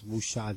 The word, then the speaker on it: wuxia: And now this is a wuxia film, or wuxia, depends on how you, I guess wuxia is the correct pronunciation.